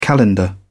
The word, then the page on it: calendar: British English